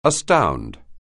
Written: astound

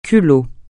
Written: culot

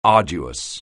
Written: arduous

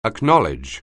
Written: acknowledge

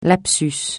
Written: lapsus

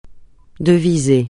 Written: deviser

